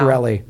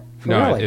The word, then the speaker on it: Farrelly: Farrelly. No, but it's pronounced Farley.